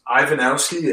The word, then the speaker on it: Ivanowski: It's Ivanowski, NBA. This is my last name. And then NBA, I'll spell that real quick. I-V-A-N-O-W-S-K-I, NBA.